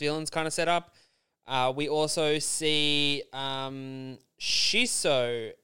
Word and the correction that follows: Shinso: I think I'm pronouncing his name wrong. Shinso, the gentleman, the young boy Shinso, who can.